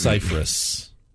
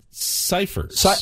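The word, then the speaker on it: Scifres: It's actually pronounced Cyphers, not Cyphers.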